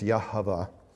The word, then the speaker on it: Yahweh: I believe the proper pronunciation is Yahweh, but they would not do that.